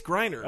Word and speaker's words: Griner: Is it Griner, not Griner? – I think it's Griner.